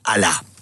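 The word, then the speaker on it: alá (ἀλλά): Él utiliza de las cuatro conjunciones adversativas que hay en el griego, él utiliza la más fuerte, que es alá. Se escribe A-L-L-A, ¿verdad? Como aliá, pero es alá.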